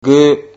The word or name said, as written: gu